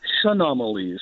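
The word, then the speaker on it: Chaenomeles: And that's why I was actually taught how to say it, Chenomalee. – Well, it's a little more French and a little bit easier to say.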